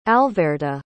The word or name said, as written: alverda